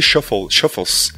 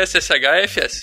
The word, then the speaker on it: SSHFS: E como que você pronuncia esse nome? Eu chamaria de Shuffles. – Ah, já não sei, cara. Pra mim é SSHFS.